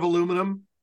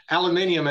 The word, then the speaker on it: aluminium: I think you were telling me before still a lot of aluminum. – Or aluminium as it's pronounced.